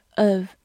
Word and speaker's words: have: Indeed，have，h a v e，have，h 发，字母 a 发 a，v e 发 v，have，或者在弱读的情况下，也可以读成 have 或者是 of。好，它是一个动词，表示有。比如说，have fun 就是玩得开心，have fun。 have a rest 就是休息，rest 就是小憩、休息的意思。